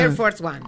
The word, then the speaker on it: Air Force One: El tipo se subió al avión, al avión presidencial, al Air Force One. ¿Lo digo bien? – Air Force One. Perfecto.